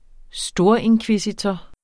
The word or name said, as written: storinkvisitor